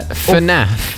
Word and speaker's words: FNAF: Yeah, I think it's normally pronounced together FNAF.